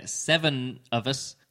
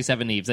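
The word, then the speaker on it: Seveneves: I thought it might be pronounced Seven of Us, because it's like seven and then seven backwards at the end. Seven of Us. Seven Eves. But it's actually Seven Eves.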